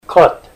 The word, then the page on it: kot: Ninilchik Russian